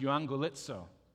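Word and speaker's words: euangelizo (εὐαγγελίζω): It's pronounced euangelizo.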